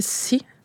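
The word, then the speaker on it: assi: Ça s'écrit A-S-S-I, c'est le mot pour terre dans la langue Inuit-Monde. Mais chez moi, dans le dialecte, on va le prononcer si. Fait que c'est vraiment comme, c'est comme le mot en anglais pour la mer,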